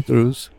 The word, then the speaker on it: Druze: And so she says, you're a druze. Is that how you say it? I said, yeah, madame, I'm a druze.